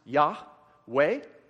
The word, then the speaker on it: Yahweh: That would be pronounced Yahweh. We've heard that one, right? Yahweh.